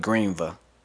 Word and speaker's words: Greenville: And if you live in North Carolina, you call it Greenva.